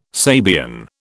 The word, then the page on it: Sabian: British English